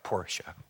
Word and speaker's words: Porsche: I think it was a 911 Porsche. You got to say it right, Porsche.